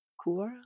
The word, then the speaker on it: Quora: Is that the way you pronounce Q-U-O-R-A? – Sounds legit. – Quora? – Quora? – Quora. I think it's Quora. – Quora? – When people ask. – Yeah. – Yeah, like a quorum.